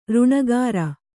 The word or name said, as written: ಋಣಗಾರ